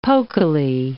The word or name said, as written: pokily